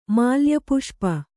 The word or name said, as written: ಮಾಲ್ಯಪುಷ್ಪ